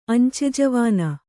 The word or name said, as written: ಅಂಚೆಜವಾನ